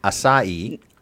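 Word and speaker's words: açaí: I like the name, and I thank you for helping me learn how to pronounce acai.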